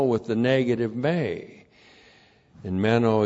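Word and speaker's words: meno (μένω): M-E-N long O, meno. So here's our old friend, meno, repeated in the second half of verse 9.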